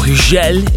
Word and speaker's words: Hugel: Hugel, or Hugel, if you're French and you say it right, with Ultranate, Moving to the Sun.